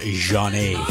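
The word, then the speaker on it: Zhané: Je schrijft het met een Z. Z-H-A-N-E met een streepje. En dat spreek je uit als Janet. Janet, Janet.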